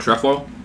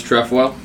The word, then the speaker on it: trefoil: It's just T-R-E, tre. If it was an I, it'd be trefoils.